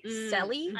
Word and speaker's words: Cely: And it's spelled, her name is spelled C-E-L-Y, Selly.